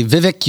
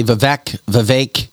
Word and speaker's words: Vivek: You can say Vivek, you can say Vivek. I heard all three.